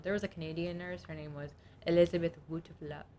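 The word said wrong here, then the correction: Wettlaufer: I can't say her last name. It says it's pronounced Wetlaufer, Elizabeth Wetlaufer, who murdered elderly patients in the nursing homes where she worked.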